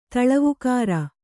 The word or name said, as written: ತಳವುಕಾರ